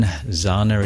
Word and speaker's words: Xana: And just in case you're wondering, Zana is spelt X-A-N-A.